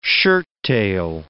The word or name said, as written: shirttail